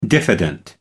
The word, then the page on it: diffident: American English